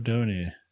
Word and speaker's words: Dana: Her name is pronounced Donna in the game. – Lacrimosa of Dona.